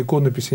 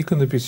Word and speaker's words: иконопись: Замечу еще раз, телезрителям, мы намеренно ставим ударение иконопись, а не иконопись, считаю, что это более правильное произношение.